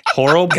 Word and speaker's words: Horlbeck: Horlbrook. How do you say your last name? Horlbrook. – Horlbeck. – Horlbeck. – God dang.